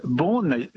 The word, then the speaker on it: Beaune: How do you say it then? – Well, Beaune, you said it very well.